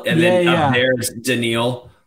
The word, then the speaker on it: Danielle: Let's move on to wide receiver, because have you read, by the way, the commenters are telling us how to pronounce it. It's Daniil.